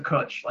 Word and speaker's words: cwtch: C-W-T-C-H, pronounced cutch, is the Welsh word for a cuddle or hug, but also has a second meaning of a cubbyhole or cupboard, a small space in which to store things safely.